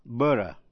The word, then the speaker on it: borough: Có hai cách đọc: Borough, B-O-R-O-U-G-H, Borough, theo kiểu Anh, và Borough, theo kiểu Mỹ. New York City has a 5 boroughs. Nguồn gốc từ chữ B-U-R-G.